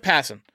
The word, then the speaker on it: Passan: Jeff Passan. Why do I always say Passan? That's how I read it, but it's Jeff Passan. I put the emphasis on the wrong syllable.